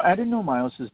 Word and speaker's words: adenomyosis: And can you clarify, because I never know how to say it, adenomyosis. – Yeah, you got it right. – Adenomyosis, that's how it is, how you say it.